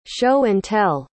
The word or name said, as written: show-and-tell